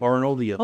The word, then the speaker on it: Arnoldia: I read your piece in the Arnold Arboretum's magazine, Arnoldia. Mm-hmm. Did I say that right? – Yep. Arnoldia.